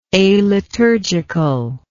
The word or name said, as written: aliturgical